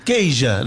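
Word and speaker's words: Queixa: Keisha. Lo dije bien, ¿no? – Lo dijo bien.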